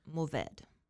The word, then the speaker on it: Mourvèdre: I pronounce this varietal as Mourvèdy. It is a French word. Mm-hmm. Mourvèdy. – Mourvèdy. – Mourvèdy. And it's spelled Mourvèdre. Mourvèdy.